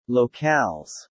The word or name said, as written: locales